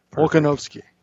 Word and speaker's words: Volkanovski: Alexander Volkswagen? – Oh, my God. – How do you pronounce that? – That's why I have you reading the names. – Volkanovski.